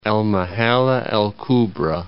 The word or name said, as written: El Mahalla El Kubra